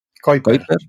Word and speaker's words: Keuper: Kuiper. Hay gente que dice Keuper. Lo lo dije mal. – No hice mal, vale, así lo aprendo. Es que yo iba a decir Kuiper, pero digo, algún profe decía Keuper y digo, no sé cómo va a ser. – A ver, si tú dices Keuper, la gente lo escribe bien. Si dices Kuiper, hasta que te enteras que se escribe Keuper, pueden pasar dos años.